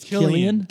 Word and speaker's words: Cillian: And I got corrected on his name, but I'm still going to say it wrong. Cillian Murphy, good actor. – It's Killian. – Killian. – It's Killian. – Yeah, it's Killian Murphy.